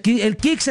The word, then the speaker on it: KIXX: El Kicks se escribe KIXX.